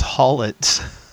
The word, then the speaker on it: Hawletts: It's spelled H A W L E T T S. But yeah, you're right. It's pronounced Hallett's.